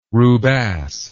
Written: rubasse

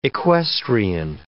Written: equestrian

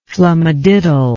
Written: flumadiddle

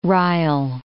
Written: rile

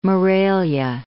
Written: Morelia

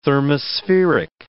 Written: thermospheric

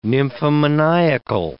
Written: nymphomaniacal

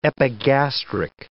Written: epigastric

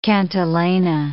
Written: cantilena

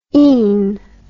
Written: ene

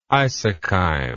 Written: isocheim

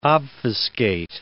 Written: obfuscate

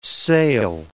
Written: sale

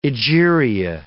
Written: Egeria

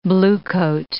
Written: bluecoat

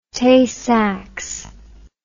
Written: Tay-Sachs

